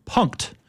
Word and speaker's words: Punkt: The name of this is Punked, which is spelled P-U-N-K-T if you're scoring at home.